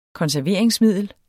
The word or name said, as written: konserveringsmiddel